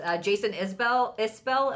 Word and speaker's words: Jason Isbell: Jason Isbell, am I saying that correctly? – Yes.